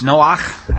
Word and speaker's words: Noach: Noach actually is the Hebrew way that it's generally pronounced, in the eyes of Yod-Heh-Vav-Heh.